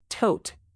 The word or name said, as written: tote